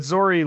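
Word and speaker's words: Zorii: In the character lineup, her name's actually listed, and it's Z-O-R-I-I. I imagine that's Zori. I don't know how you guys pronounced it. – Yeah, that's Zori. Yeah. – All right, Zori.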